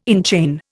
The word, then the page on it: enchain: Indian